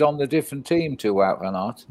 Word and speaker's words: Wout van Aert: John, it's Wout van Aert. – That's it. Wout van Aert, John.